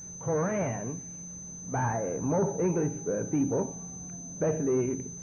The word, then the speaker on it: Quran: So you have to roll that tongue like the Spanish do. Quran. It's called Quran by the Muslims, and that's the proper Arabic pronunciation, Quran. That book,